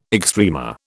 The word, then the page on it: extrema: British English